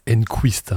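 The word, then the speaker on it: Enqvist: Enfin, sur 31 éditions de 93 à 2023, il y a 9 victoires françaises, dont 3 de Joey Fritzonga, qui avec Thomas Anquiste et Marc Rosset sont les joueurs les plus titrés de cette Open 13 Provence. – Thomas Enquist. Hein, euh, Enquist.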